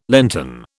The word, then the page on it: Lenten: British English